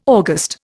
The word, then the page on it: August: Indian